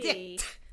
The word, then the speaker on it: Dict: I have to pronounce the T. – Yeah, you have to pop that T. – Dict.